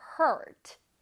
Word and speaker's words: hurt: Hurt 아니고 heart도 아니야, 얘들아. Hurt입니다. 선생님 모양 잘 따라해봐. Hurt. Hurt. 모여졌어요. 그리고 R이기 때문에 혓바닥은 뒤로 갑니다. Hurt.